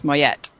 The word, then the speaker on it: Mouette: It's got an E at the end, so you would pronounce the T's, Moyette. – Okay, Moyette. But I didn't know how to do the O-U-E, so Moyette?